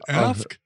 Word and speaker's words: AFK: Do you pronounce it AFK? – AFK, yeah, you AFK.